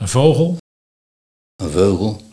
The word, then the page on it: Veugel: Nieuw lekkerlands